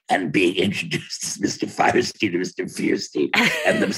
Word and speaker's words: Fierstein: It was my father's last name. And he pronounced it Fierstein. So I figure that's the way you pronounce it.